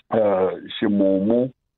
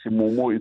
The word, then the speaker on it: ximumu: The first one, it's... Shimumu. – And how do we spell that? X-I? – X-I-M-U-M-U. – Yeah. – Yeah. So it's like, when we move from Shimumu, then we will move into Shishikana.